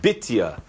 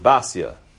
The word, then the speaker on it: Bitya: In the Chumash, it's written Bitya. But no one really pronounces that. Everyone pronounces it Basya. Batya. So we'll be incorrect, but at least we'll be safety in numbers. We're going to pronounce her name wrong. We'll call her Batya.